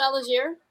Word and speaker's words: Allgeier: Yeah, Allgeier. Is it Allgeier? – Yep.